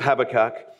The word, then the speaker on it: Habakkuk: There are a few different pronunciations of this strange name, but we're going to go, for the sake of consistency, with Habakkuk, all right.